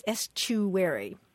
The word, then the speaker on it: estuary: And usually the pronunciation is estuary, like eschew, which is kind of surprising.